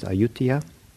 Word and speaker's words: Ayutthaya: In the late 1800s or so, there was a in the ruins of the ancient old capital of Thailand, I think it's pronounced Ayutthaya, was a 9-foot plaster Buddha that I guess was quite nice.